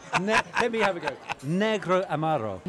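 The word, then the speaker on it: Negroamaro: Tell me how to pronounce it. – Negro Amaro. – Negra Amaro. – No, Negro Amaro.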